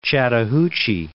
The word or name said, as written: Chattahoochee